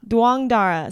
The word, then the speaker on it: Douangdara: Douangdara.